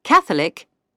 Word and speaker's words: Catholic: The stress is on the last but one syllable for the words with an ick ending, except Catholic and heretic.